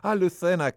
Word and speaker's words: Lucena: My second one was in Lucerno. – It's in Switzerland. – It's near Cordoba. – Lucena, Cordoba. – Pronounced it incorrectly, okay.